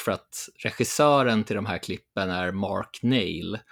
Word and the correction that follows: Neale: eh, med reservation för att jag uttalar det fel. Eller Neil kanske. Neil låter mer. Ja, Neale. Ja.